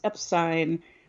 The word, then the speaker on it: Epstein: Even though we clearly have declared, have evidence, go back and listen to our episodes where we clearly state why Brian's last name is pronounced Epstein.